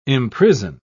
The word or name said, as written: imprison